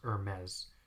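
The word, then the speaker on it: Hermès: If you have no idea what brand I'm talking about, it's just spelled Hermes. Mm-hmm. But it's pronounced Hermes. The H, like most of the letters, are just silent.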